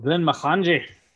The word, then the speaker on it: Glenmorangie: So Glenmorangie is how I'm going to pronounce it from now the fuck on. – Oh, wow. – Okay, so for posterity, Glenmorangie is probably one of my favorite whiskeys.